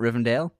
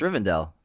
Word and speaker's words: Rivendell: Strider's like, I gotta get you to Rivendell. – Rivendell. – Is it Rivendell? – I'm pretty sure it's Rivendell, bro. – Rivendell, not Rivendale? – I think it's Riven, then D-E-L-L.